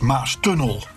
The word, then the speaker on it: Maastunnel: Vervolgens, in een schone auto op weg naar mijn vriendin, reed ik door de Maastunnel. Zegt hij dan, in Rotterdam ligt de klemtoon op tunnel en niet op Maas. Dus het is Maastunnel.